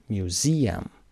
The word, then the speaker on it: museum: Museum سه سیلابیه و استرس قسمت وسط. Museum.